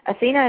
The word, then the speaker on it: Athena: I'm on Facebook, Athena Horton, H-O-R-T-O-N. Athena is spelled A-T-H-E-N-A.